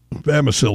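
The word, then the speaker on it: Bamisile: Like John said, Bissimile at VCU, Bamisil, excuse me, I'm pronouncing it wrong, Bamisil. Give me a minute. Let me say it 10 times. I got it right.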